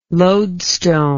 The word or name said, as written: loadstone